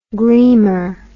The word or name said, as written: gleamer